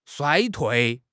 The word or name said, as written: 衰退